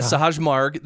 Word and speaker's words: Sahaj Marg: How do you say that? – Yeah, Sahaj Marg.